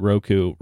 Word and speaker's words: Roku: So Roku, people pronounce it differently. I pronounce it Roku, reported a narrower than expected Q4 loss of 13 cents per share.